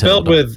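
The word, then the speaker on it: Mathilde: That's what I thought, but sometimes it sounded like they were just saying it's Matilda. It's spelled with like an H and an E. It's M-A-T-H-I-L-D-E. – Right.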